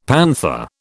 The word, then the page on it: panther: British English